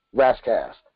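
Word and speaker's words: Ras Kass: His name is spelled R-A-S-K-A-S. KASS, Raskass.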